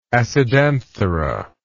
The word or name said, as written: acidanthera